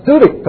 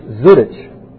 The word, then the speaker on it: Zurich: I'm going to Zurich tomorrow. He said, oh, no, it's Zurich. Zurich.